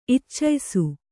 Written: ಇಚ್ಚಯಿಸು

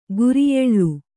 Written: ಕರಿಯಾಲೆ

